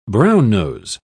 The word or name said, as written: brownnose